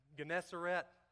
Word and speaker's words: Gennesaret: Gennesaret, that's exactly how you say that, because that's what Webster Dictionary says on the computer you can say pronounce, and that is draught.